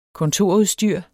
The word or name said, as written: kontorudstyr